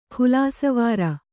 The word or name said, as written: ಖುಲಾಸೇವಾರ